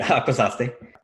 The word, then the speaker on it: Akwesasne: And this quote is from Vincent Schilling, who is a Akwesasne Mohawk. I'm sorry if I said that wrong. – No, that's right, Akwesasne.